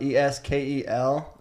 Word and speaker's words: Eskel: It's spelled E S K E L, and then my last name Grant.